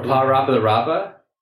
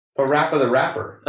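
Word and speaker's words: PaRappa the Rapper: How are you pronouncing that? – Pa Rapa the Rapa? How do you pronounce it? – Pa Rapa the Rapper. – Oh, okay. – Because he's a rapper.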